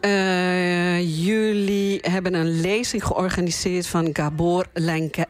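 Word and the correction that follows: Lengyel: Lengai. Lengai, ja, Zeg ik het goed? – Ja.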